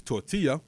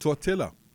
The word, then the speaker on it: tortilla: By the way, the word is tortilla, not tortilla. I don't know who needs to hear this, but the word is tortilla. It's not tortilla. You don't pronounce the L's.